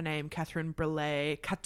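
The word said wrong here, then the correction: Breillat: That's Brea.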